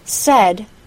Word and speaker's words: said: Here's one last example, this time with the A-I-D spelling. Said, S-A-I-D, doesn't rhyme with paid, P-A-I-D. It rhymes with bed, B-E-D. Said, bed. And plaid, P-L-A-I-D, doesn't rhyme with paid either.